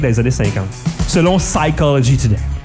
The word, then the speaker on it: Psychology Today: Psychology Today. Ce qui m'occasionne aussi le fait de rappeler qu'on prononce pas le P dans Psychology Today.